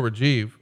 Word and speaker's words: Rajiv: A friend of the show, Rajiv, wants to know why uh, was putting Heidenreich with Animal.